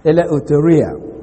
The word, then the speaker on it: eleutheria: Eleutheria is spelled E-L-E-U-T-H-E-R-I-A, eleutheria.